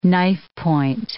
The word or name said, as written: knifepoint